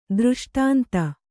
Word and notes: ದೃಷ್ಟಾಂತ: dřṣṭānta

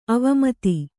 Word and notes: ಅವಮತ: avamata

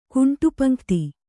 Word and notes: ಕುಂಟುಪಂಕ್ತಿ: kuṇṭupaŋkti

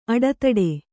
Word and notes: ಅಡತಡೆ: aḍataḍe

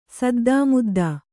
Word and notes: ಸದ್ದುಮುದ್ದು: saddu muddu

